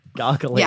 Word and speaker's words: gargalesis: Gargalesis. – Gargalesis. Yeah, look, I don't know who named these things. Wasn't me. I'm not a tickle scientist. But yeah, no. So gargalesis is a much harder type of tickle, which is, you know, when people tickle you in the ribs or under the armpits.